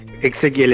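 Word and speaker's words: Exequiel: ¿Se dice Ezequiel tu nombre? – Ezequiel, exactamente. – Ah, bien. Porque viste que unos tienen la X y sin embargo lo pronuncian como una jimena, claro, simena. Lo hacen más uniforme y ahí tenés con Z, tenés con S, tenés con X. – Bueno, vos sos Ezequiel y pronunciás Ezequiel. Claro, para algo tenés una X, es distintivo. – Exacto.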